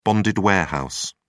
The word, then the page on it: bonded warehouse: British English